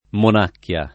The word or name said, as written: monacchia